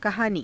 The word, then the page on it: kahaanii: Hindi